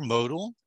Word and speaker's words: Motyl: It's Alexander Motyl.